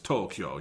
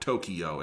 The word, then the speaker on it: Tokyo: Like Tokyo, we technically say wrong. It's Tokyo, you know, not Tokyo, as a lot of Americans say it.